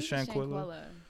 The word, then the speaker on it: Shanquella: But you know, just overall, back to the Shanquella thing, I keep saying, I don't remember how to say her name properly. Is that the way to say it? – Was it Shanquella? – Yeah.